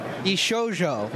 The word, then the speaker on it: Bishojo: And one of the conversations we've had is, how do you pronounce that name? – Bishojo. – Bishojo. Now we know.